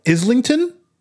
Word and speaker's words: Islington: So he goes to, I hope I say it right, Islington? – Yes.